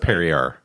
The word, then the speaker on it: Perrier: It's pronounced Perrier. – It's not Perrier, which is Perrier.